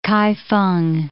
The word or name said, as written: Kaifeng